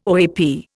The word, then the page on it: OAP: Indian